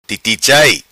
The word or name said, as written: Titi Chai